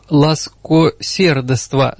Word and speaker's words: ласкосердества: Не всем бо общее жития, ласкордества. – Ласкосердества. Здесь вот опечатка? Раньше. – Нет, просто так писалось и, возможно, произносилось, но сейчас для нашего уха, современного такого православного человека, да, вот и в церковно-славянской традиции, ну, как сейчас до сих пор читается «бервно». Mm. Не бревно, а бервно, да? Вот такой же случай. Uh-huh. Здесь ласкосердества, лучше сказать, да.